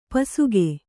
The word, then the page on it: ಪಿಸುಕು: pisuku